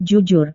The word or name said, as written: jujur